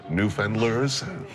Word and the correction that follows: Newfoundlanders: Newfoundlanders, Newfoundlanders see themselves?